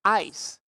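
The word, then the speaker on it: ice (εἰς): It's ice in the Greek. It's pronounced ice.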